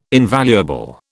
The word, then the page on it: invaluable: British English